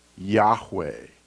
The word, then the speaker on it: Yahweh: And from the study of this and also the structure of the Hebrew language, most scholars today believe that Y-H-W-H is pronounced as Yahweh.